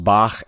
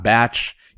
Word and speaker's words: Bach: You need to know that it's pronounced Bach and not Batch.